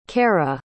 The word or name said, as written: kaira